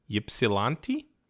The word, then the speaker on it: Ypsilanti: Y-P-S-I-L-A-N-T-I. Ypsilanti? – Ypsilanti.